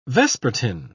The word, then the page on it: vespertine: British English